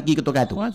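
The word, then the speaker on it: Kito Kato (キットカット): O sea, se pronuncia prácticamente igual. Sí. O sea, tú llegas a la tienda y dices, Wachiwari Kito Kato. Y te dan un Kit Kat.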